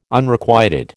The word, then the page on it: Unrequited: American English